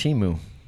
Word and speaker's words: Temu: It's Temu. It's Temu. Gong Hei Fok Choi. – I hope you get rich. – My dog. – If you keep shopping Temu, you will.